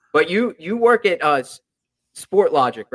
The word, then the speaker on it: Sportlogiq: Logic with a Q. I love that. – Yeah, it gets mispronounced a lot, so good on you for getting it right. We're in Montreal, so like people will throw the French kind of twang to it or Sport Logic IQ.